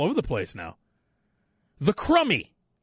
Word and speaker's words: Crumby: It's Crummy as in crumbs that fall off of things that you have to clean up.